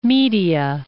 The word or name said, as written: Media Atropatene